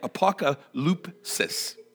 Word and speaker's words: apokalypsis: And if you want to go back to, I kind of conferred with Janet because she's my resident Greek expert, and the Greek word for revelation would be apokalypsos, apokalypsis. I just totally butchered that. I was really hoping to really just totally impress all of you, and I just butchered it. Man, apokalypsis. There we go.